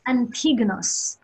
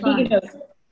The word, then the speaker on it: Antigonos: That's why it's hard to say. It's Antigonos. So now I'll say it. – Antigonos.